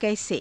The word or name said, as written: kaise